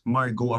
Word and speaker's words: Margaux: Kunyari, Margo. Na M-A-R-G-A-U-X. Margo ang pagkakapronounce.